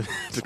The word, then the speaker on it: Smaug: Du, du, du Smaug? kannst das auch schon so Ossi-mäßig sagen, ne? Ja. ja ja, kein Amerikaner weiß, wie man es ausspricht, aber wir wissen es, weil wir AU richtig aussprechen können.